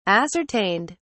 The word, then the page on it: ascertained: British English